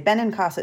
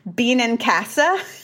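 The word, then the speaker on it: Benincasa: But what I love is when people do what I call the Canadian pronunciation, which is, I forget if they do Sarah or Sarah. Either way, I'm fine. It's Sarah, Sarah, whatever, Sarah. It's okay. But they go, Bean and Casa.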